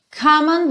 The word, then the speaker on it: come on: Então, lembra aqui de juntar o come no on, porque o E do come não é pronunciado. Então, você nunca diz come e sim come, come. Ou seja, já junta esse M no on, come on.